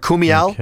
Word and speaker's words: Kumail: Kumali Nanjiani. – Okay. – Right? Or Kumail, excuse me. I mispronounced his name.